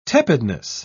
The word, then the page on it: tepidness: British English